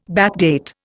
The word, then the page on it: backdate: Indian